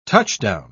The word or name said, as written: touchdown